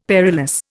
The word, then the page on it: perilous: Indian